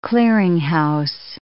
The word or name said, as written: clearinghouse